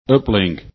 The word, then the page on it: uplink: english